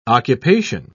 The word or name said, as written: occupation